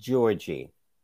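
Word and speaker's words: Giorgi: Her name is pronounced Georgie.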